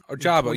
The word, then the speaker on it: Ojabo: People kind of talk about him as like, is it a David Ojabo from Michigan? – Ojabo, yeah, David Ojabo from Michigan.